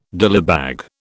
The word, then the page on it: dillybag: British English